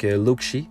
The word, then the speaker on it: Lucxi: C'est L-U-C-X-I. Et le X se prononce Ch. Donc, Luxi.